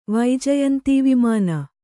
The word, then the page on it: ವೈಜಯಂತೀವಿಮಾನ: kannada